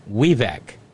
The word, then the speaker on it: Vivek: Vivek Ramaswamy It's pronounced like Weevac.